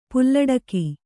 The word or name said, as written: ಪಾಲಡಕೆ